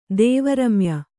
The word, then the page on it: ದೇವರಮ್ಯ: Kannada